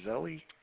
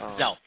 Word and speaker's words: Zelle: I guess it's pronounced Zelle or Zelly." – Yep, Zelle. Zelle.